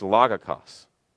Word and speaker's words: logikos: I'm going to pronounce this word in Greek only because you'll recognize it and you'll help you understand it, okay? It's logikos.